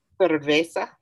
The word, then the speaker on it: cerveza: Did I not say it right? Cerveza, cerveza. – Cerveza, yeah. Cerveza. You put too much accent in there all of a sudden, homie.